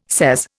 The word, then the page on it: sez: Indian